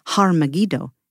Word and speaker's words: Har Megiddo: This is convenient, perhaps, because Armageddon, or as we say in Hebrew, Har Megiddo, is located in the Jezreel Valley. Armageddon is the translated, differently pronounced version of the original name.